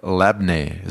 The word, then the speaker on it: labneh: All right, let's move on to this lemony cauliflower over labneh.